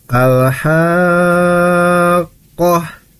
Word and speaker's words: Al-Haqqah: Adik Marzia udah betul di, tapi di ayat 1 tadi dengarnya kohnya itu dibacanya panjang gitu ya, padahal sebetulnya harusnya dibacanya pendek. Maka harusnya Al-Haqqah Koh, gitu ya. Malhaqqah, gitu ya.